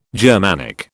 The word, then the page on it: Germanic: British English